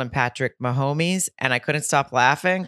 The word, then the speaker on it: Mahomes: It's Mahomes.